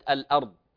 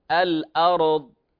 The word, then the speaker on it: الأرض: لاحظوا الراء في كلمة الأرض، فقلقلة الراء البعض قد يقرأها الأرض، هذا خطأ، فإما أن تقلقل أو تكرر هذا خطأ، الأرض هذا خطأ، فالأصل أن تنطق ساكنة بحركة من غير تكلف، الأرض وهكذا.